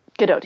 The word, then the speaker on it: Gadot: And, I could feel like there's a lot of that happening with Gadot in the film, where she would just, or Gadot, I'm saying that wrong. It's Gadot, right? – Gadot, yeah.